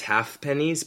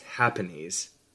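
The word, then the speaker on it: ha'pennies: I will correct you though in saying it's actually pronounced pennies. – Oh, like in the English way. – Yeah, I always thought it was half pennies, but it's happenies.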